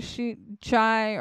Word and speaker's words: Chi: On the evening of November 12, 2022, Chapin and Kernodle are seen by BF at the Sigma Chi or Chi house. It was a chi, that's how you pronounce it, of the University of Idaho campus at 735 Nez Perce Driven.